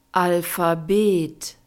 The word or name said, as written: alphabet